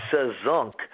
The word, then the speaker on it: SAZZUNK: It's SAZZUNK, which is actually a combination of three musical terms, salsa, jazz, and funk, hence the word SAZZUNK. – Salsa, jazz, and funk. Is that? – Yeah, that's funky cool. Music, F-U-N-K, funk.